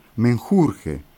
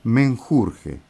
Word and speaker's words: menjurje: En España dicen menjunje, le han quitado la N. En México hasta Venezuela, menjurje, por eso la canción que escuchamos, que corresponde a un grupo mexicano, que se llama el personal, pronuncia menjurje. Y en el cono sur, desde Venezuela para el sur, en México, en la Argentina, decimos menjunje.